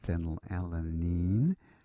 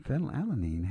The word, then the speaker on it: phenylalanine: One of them happens to be the amino acid phenylalanine. If you're not familiar with it, it's spelled P-H-E-N-Y-L. A-L-A-N-I-N-E,